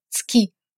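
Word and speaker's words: цки (月): Следует отличать обязательную редукцию, как в слове «цки» – «луна», и необязательную, как в слове «кику» – «хризантема».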